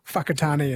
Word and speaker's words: Whakatane: I think the WH is pronounced F, like Whakatane is WH, Whanganui WH instead of F.